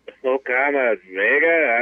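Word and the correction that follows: Oye Como Va: how do you say that? Vega? – Oi, como va? – Okay, the Triumph did fight the good fight.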